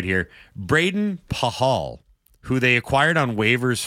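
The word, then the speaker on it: Pachal: Now, if you look at his name, it should be Braden Pakal. It's P-A-C-H-A-L. But I went and looked because we're talking about pronunciation. Braden Pahal.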